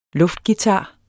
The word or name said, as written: luftguitar